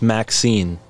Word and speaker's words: MXene: And significantly, these performance numbers did not waver when the how do we pronounce it? Mxene? Mxene? – They said it's pronounced Maxine, like the name, Maxine. Like the female name Maxine.